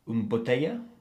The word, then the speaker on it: bouteille: bouteille d'eau